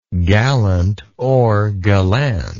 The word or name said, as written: gallantness